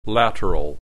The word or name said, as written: lateral